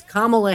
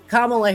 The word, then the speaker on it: Kamala: It's Kamala Harris. I hope I'm saying that correctly, like a comma, the punctuation mark, Kamala Harris.